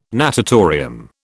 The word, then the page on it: natatorium: British English